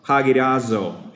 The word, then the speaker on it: hagiazo: Well, not to bore you with a lot of Greek definitions, this word hallowed is the Greek word pronounced hagiazo, H-A-G-I-A-Z-O.